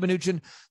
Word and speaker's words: Mnuchin: Mnuchin? – Steve Mnuchin, the Secretary of Treasury for the President Trump.